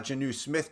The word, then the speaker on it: Jonnu: All right, standard league, Hunter Henry or is it Jonnu Smith? Is that how you say his name? – Yes, you got Jonnu Smith correct and go.